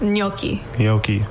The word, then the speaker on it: gnocchi: Homemade gnocchi.